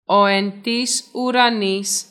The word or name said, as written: Πάτερ ημών